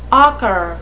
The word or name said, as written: ocker